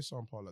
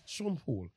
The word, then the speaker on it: Sean Paul: Why say Sean Paul like that? What do you mean? You're Jamaican. I don't know why I said it right. But you said it right. – But I'm British. Sean Paul.